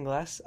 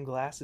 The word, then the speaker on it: Glass: My name is Jackson Glass. I run a sketch comedy YouTube channel. My last name Glass is spelled with one S, and I will now rank every Taylor Swift album from least favorite to favorite.